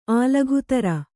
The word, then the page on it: ಆಲಘುತರ: alaghutara